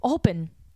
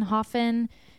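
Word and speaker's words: Höfn: So I can't remember how it came up, but I was talking to a woman at a hotel saying, we're going to this place called H-O-F-N, Hoffen. She goes, oh, it's actually pronounced open, like open. Open.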